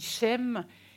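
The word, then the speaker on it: Shem (שם): Le nom en hébreu se dit Shem et est très proche d'un autre nom qui se prononce Sham et qui veut dire là-bas.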